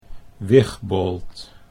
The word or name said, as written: Wigbold